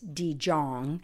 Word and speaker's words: de Jong: It's by a Dutch author named Mendert de Jong, and his name is spelled, his last name is capital D-E and then capital J-O-N-G. So you might think it's de Jong, but it's de Jong, it's Dutch, and of course Mendert is M-E-I-N-D-E-R-T.